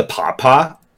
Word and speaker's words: pawpaw: Is that, like, spelled like your dad? Like a papa? – No, P-A-W-P-A-W.